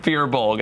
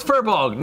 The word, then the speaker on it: Firbolg: and it was me pronouncing it Firbolg, and I went, fuck!